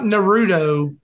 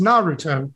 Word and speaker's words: Naruto: It's not Naruto as we say it down here in the South. It's Naruto.